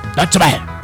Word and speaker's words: Deutsche Welle: Este, resulta que la Deutsche Welle. – Deutsche Welle. Es que lo pronuncias como si fuera italiano.